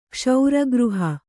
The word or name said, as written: ಕ್ಷೌರಗೃಹ